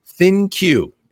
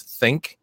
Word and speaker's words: ThinQ: We can't talk too much about this, but they did let slip some information on the LG G7 ThinQ, is how you say that. I was thinking it was Think, a funky way of saying Think.